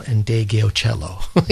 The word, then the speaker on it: Ndegeocello: And I thank Brian Saunders for the correct pronunciation of Michelle Ndegeocello.